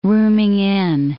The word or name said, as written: rooming-in